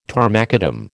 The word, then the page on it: tarmacadam: American English